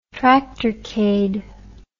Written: tractorcade

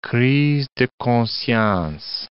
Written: crise de conscience

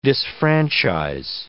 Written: disfranchise

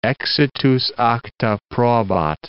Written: exitus acta probat

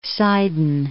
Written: Sidon

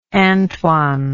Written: antoine

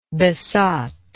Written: besottingly

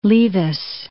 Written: Leavis

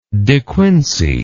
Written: De Quincey

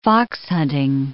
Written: foxhunting

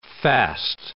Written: fast

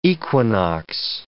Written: equinox

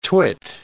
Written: twit

